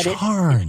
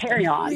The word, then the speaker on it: carrion: It's carry on. – Really? Is it C-A-R-R-I-O-N? Is that how you spell it? – I think so. – Okay. – Yeah. But I had never heard it pronounced that way because, all my people always said charn.